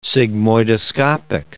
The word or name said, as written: sigmoidoscopic